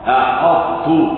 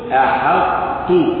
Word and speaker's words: أحطت: أن تفخم حرف الحاء فتقول أحاط أحطت هذا خطأ ولكن قل أحطت أحطت أحط أحط أحط هذا صواب أحطت